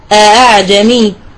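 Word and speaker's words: أأعجمي: هناك أولا الهمزة المسهلة بينها وبين الألف مثل أعجمي، أنا لم أنطق هنا همزة كاملة، بالنسبة للهمزة الثانية هذه في رواية حفص عندنا الهمزة الثانية مسهلة.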